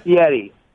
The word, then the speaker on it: Fieri: It's Fieri. It's Fieri. See, in Italian, E-R-N-I is rolled. It's Fieri. It doesn't have a T in it. Everybody wants to put a T in it, but it's Fieri.